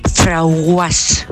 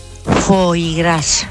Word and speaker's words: foie gras: Pues yo tenía una clienta que cuando me iba a comprar cierta cosa, decía, dame un frauguas. A ver si sabéis lo que es eso, porque yo el primer día no lo sabía. Foie gras. – ¿Qué? – Porque decía que tenía un nombre muy raro y ella no lo sabía pronunciar. Entonces lo decía así, frauguas.